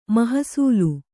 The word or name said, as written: ಮೋಹಸಲೆ